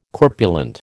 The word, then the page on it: corpulent: American English